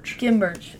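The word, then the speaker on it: Khem Birch: Kim Birch.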